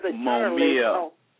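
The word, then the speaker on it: Mumia: Momaya Abu-Jamal. – Oh, his name is Momia. Momia Abu-Jamal.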